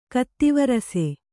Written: ಕತ್ತಿವರಸೆ

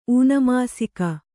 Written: ಊನಮಾಸಿಕ

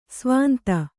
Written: ಸ್ವಾಂತ

